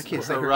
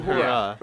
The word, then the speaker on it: Uhura: He gets Uhura. I can't say her name right. – Uhura. Uhura.